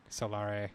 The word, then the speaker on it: Solare: And actually, our name is spelled Solar, and then you stick an E at the end. And that's it. – Solare. – Yep.